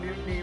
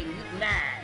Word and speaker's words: Mutiny: LWAFLMNOYT on Mutiny Radio. Mutiny. – Mutiny! It's pronounced mutiny. – Mutiny! – It's pronounced mutiny. – Mutiny! – Well, my turnoffs are guys who say mutinay.